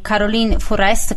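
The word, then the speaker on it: Caroline Fourest: Ecco, questa è tratta da diretta, è l'opera prima di Caroline Forest, correggimi se lo pronuncio male, ovviamente, perché io qua francese. – No, pronunciato bene.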